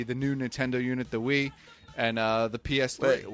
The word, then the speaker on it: Wii: Because it's called the Wii, and it's spelled W-I-I, okay. – But it's pronounced Wii.